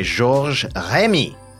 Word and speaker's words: Georges Rémy: Created in 1929 by Georges Rémy, which is how it's pronounced properly, better known as Hergé, Tintin, or Tintin, became a worldwide star.